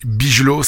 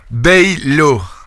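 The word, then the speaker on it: Bijlow: C'est qui Bigelow Bon voilà, Baylo. Voilà, quand c'est IJ, c'est Hey, c'est comme Wijnaldum, non, c'est Wijnaldum.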